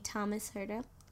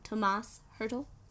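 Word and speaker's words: Tomáš Hertl: It adds up to me, Thomas Hurdle. No, Tomas Hurdle.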